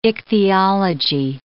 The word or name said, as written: ichthyology